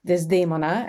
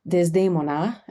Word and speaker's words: Desdemona: Desdemona, and I'll use the Italian pronunciation for the opera character,